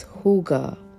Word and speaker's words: hygge: This is on Danish philosophy, which is pronounced as HYGGE or HYGGE.